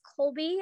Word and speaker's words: Kolby: It's Colby, and that's spelled K-O-L-B-Y, mm-hmm. and then Garrison, G-A-R-R-I-S-O-N.